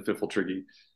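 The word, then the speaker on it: Pfifltrigg: I definitely am in the firm camp that is a silent P at the beginning of the Fiffeltriggy.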